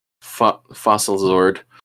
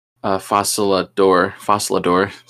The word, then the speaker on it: Fossilador: So, and like I mentioned before, they're fighting against the Fossil Zord. Fossilador. Sorry.